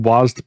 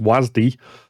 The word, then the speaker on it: WASD: WASD, which we had been pronouncing WASD, but apparently is officially pronounced WASD.